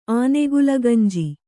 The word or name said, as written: ಆನೆಗುಲಗಂಜಿ